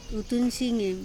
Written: utunsingem

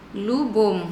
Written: lubom

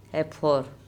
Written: ephOr

